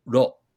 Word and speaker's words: ro: But in the Japanese Morse code, that signifies the kana syllable ro, R-O.